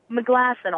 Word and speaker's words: Meglasson: Okay, in 2 minutes, you can go to Heather Meglasson, all one word. My last name is spelled Me, M-E, glass, like a glass of water, and then on. Me, glass, on.